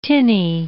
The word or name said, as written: tinny